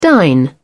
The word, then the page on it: dine out: British English